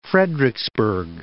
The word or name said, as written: Frederiksberg